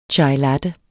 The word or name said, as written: chailatte